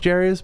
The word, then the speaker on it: Jairus: It's pronounced Jarius, but it's spelled Jairus.